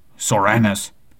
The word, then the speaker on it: Soranus: Soranus. If you hadn't known about it, Soranus is a name. Yep, and it is spelled S-O-R-A-N-U-S. So Uranus, yep.